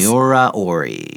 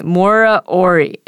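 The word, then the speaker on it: Miura-ori: Miura Ori. – Okay. Miura Ori, invented by Japanese astrophysicist Koryo Miura in 1970.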